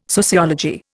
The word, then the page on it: sociology: Indian